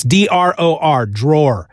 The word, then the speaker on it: Dror: It's D-R-O-R, Dror. I know his first name is pronounced Boaz because he says his name in the video on the Kickstarter, but he doesn't say his last name. So I'm just assuming Dror, Boaz Dror, with art by Valentin Ramon.